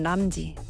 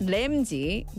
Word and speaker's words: Ramsay: 내가 울을 때도 우리 강아지 옆에 붙어 있고 행복해도 강아지 옆에 붙어 있고 우리 강아지 이름은 람지. 고든 람지 알아요? Not 램지, 남지. 다람쥐처럼 람지. 근데 Gordon Ramsay, 미국 발음으로 Ramsay 맞아요. 근데 Ramsay, 람지 맞아요. Gordon Ramsay, 스코틀랜드 사람이라서 Gordon Ramsay.